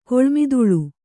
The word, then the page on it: ಕೊಳ್ಮಿದುಳ್: Kannada